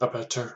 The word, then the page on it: abettor: American English